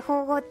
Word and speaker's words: 縫合: これって縫合って読むんですか？合ってますか？ – 危なかった。今漢字が読めたことが証明されました。